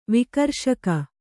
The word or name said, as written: ವಿಕರ್ಷಕ